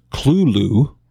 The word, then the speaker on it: Cthulhu: I believe Lovecraft himself pronounced it Cthulhu or Cthulhu. If you look at the spelling, C-T-H-U-L-H-U, to pronounce it the way he pronounced it, I think you would need some apostrophes in there, which...